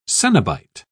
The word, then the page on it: coenobite: British English